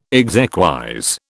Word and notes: exequies: British English

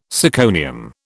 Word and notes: syconium: British English

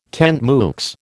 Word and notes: tant mieux: American English

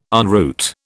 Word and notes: en route: British English

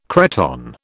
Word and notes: cretonne: British English

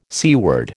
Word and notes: seaward: American English

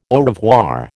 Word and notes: au revoir: American English

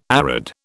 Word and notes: arid: British English

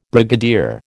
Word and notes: brigadier: American English

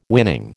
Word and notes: winning: American English

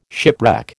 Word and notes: shipwreck: American English

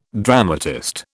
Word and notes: dramatist: British English

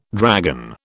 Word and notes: dragon: British English

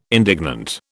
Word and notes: indignant: British English

